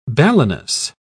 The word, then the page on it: balanus: British English